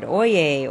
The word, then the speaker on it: oyez: Each cry starts with the standard, Oye, Oye, Oye, says Jerry Praver, town crier of San Luis Obispo County in Central California. – That's spelled O-Y-E-Z, and it's French. And in English, it would be, yo.